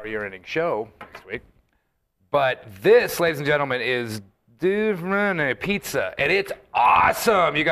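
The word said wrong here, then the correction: Due Forni: Okay, it's Duo Forni. – And I said it wrong to the guy.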